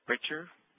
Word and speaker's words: Ritscher: My Twitter feed is Walt Richer. My last name is spelled R-I-T-S-C-H-E-R.